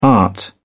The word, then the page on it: art: British English